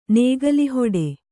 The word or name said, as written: ನೇಗಿಲುಹೊಡೆ